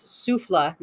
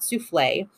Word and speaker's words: Souffle: But he definitely did the Souffle line, which I have been pronouncing it wrong. Apparently it's Souffle, not Souffle. The first hint I got of that is there's no like accent over the E.